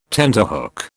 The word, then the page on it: tenterhook: British English